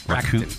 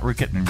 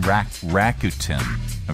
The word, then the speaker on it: Rakuten: Rakuten. – Rakuten? – Mm-hmm. – Is that how you pronounce it? – Yep. – Not Rakuten, Rakuten.